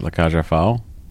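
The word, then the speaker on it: La Cage aux Folles: How do you say that? La Caja. Any idea? Uh, I've already. I've. I, you said it the first time. Is how I've always heard it. La Caja Fall.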